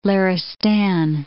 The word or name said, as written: Laristan